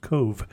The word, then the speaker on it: Cobh: I wouldn't even know how to spell it, apparently, since when you see something that's spelled C-O-B-H, you don't think that it would be pronounced Cove. – Yeah, I know. You think C-O-V-E.